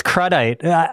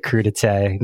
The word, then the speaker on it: crudité: I know, but whenever somebody uses a French word, I used to think it was cruddite. I have those kind of. I'm a man of the people. Snorting, Troy. I am sure you would pronounce it crudité, Mr. Canadian.